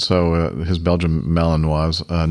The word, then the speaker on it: Malinois: Thought this would perk the interest of Rick with his Belgian Malinois. Did I say that right? – Yeah. Yeah, that's right.